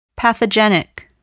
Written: pathogenic

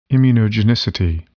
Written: immunogenicity

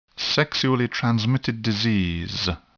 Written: sexually transmitted disease

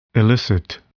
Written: elicit

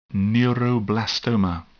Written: neuroblastoma

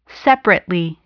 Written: separately

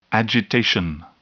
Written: agitation

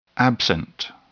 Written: absent

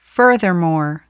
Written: furthermore

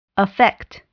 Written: affect

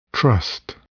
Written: trust

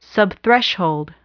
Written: subthreshold

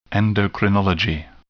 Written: endocrinology

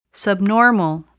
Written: subnormal

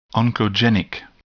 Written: oncogenic

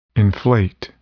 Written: inflate

